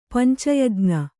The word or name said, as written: ಪಂಚಯಜ್ಞ